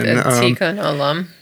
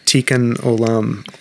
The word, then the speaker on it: Tikkun Olam: Tikkun Olam. – Tikkun Olam, or which is known as healing the world.